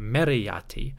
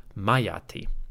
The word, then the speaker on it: Meritaten: Adapting that to the name Meritaten, we may have a pronunciation closer to Meriati, or simply Mayati.